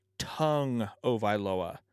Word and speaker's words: Tagovailoa: But I believe the official pronunciation of Tua's last name is Tua Tagovailoa. Like your tongue in your mouth.